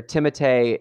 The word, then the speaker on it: Timothée: So I read an interview where Timothée is the correct pronunciation, but he doesn't want to come off like a dick, so he doesn't have anyone say that.